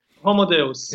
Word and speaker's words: Homo Deus: Also, you know, the book Homo Deus, am I pronouncing that right? Deus? – Yes. Homo Deus.